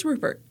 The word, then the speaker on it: Ruppert: I'll have you know, okay, this gentleman that I'm going to tell you about, his name is James Rupert, but he spells it with two P's. So I was very concerned that it was pronounced Rupert. – Yeah. – And so I had to search the archives of the internet. – It's pronounced archives. – To find a video of his trial to hear his name pronounced to make sure I was pronouncing it properly. And it is Rupert.